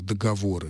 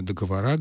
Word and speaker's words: договоры: Племен минувших – договоры. Ну, сегодня договора, говорят бухгалтеры, но, тем не менее, основной вариант все-таки этот. Значит, предположение о том, что работает заимствованный или исконный характер слова, не проходит.